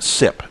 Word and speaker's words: SIP: normally pronounced SIP for SIP.